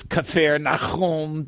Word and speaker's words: Capernaum: And I, I see a word like what that it looks like Capernaum. And I know that the actual pronunciation of it is Kafer Nahum, the city of Nahum yeah, exactly, man.